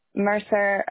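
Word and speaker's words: Mercer Island: Marcel Island. – Marcel Island? – Mercer, M-E-R-C-E-R.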